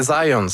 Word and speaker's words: Zajonc: Non, c'est Zajonc. – Ouais? OK. – Ouais, j'ai-tu pas dit Zajonc? – Vous avez dit plus Zajonc, mais. – J'ai prononcé un petit peu à l'anglais. J'ai prononcé mon polonais à l'anglais.